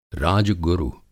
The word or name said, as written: राज-गुरु